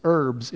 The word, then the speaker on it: herbs: And did I say that wrong? It's herbs, isn't it? It has a H, but you don't say that, Gary.